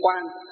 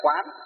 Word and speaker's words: quán: Ưng quan hay là quán, đọc là quán được, quan được.